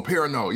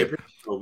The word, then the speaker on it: Perrineau: Hal Perrineau. I can't pronounce my man last name. – Oh, Perrineau. Yeah. – Perrineau, but yeah.